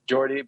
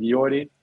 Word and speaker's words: Jordi: we usually say, if you get to England, they say like Jordi, but it's, yeah, we just pronounce it Jordi.